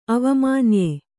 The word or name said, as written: ಅವಮಾನ್ಯೆ